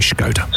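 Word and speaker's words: Škoda: You've got to say Skoda. – Skoda. – No. – Skoda. – Skoda. Listen to me. – Skoda. – There you go. – Right. – You don't say it like you're going to attack me. It's not like a war cry.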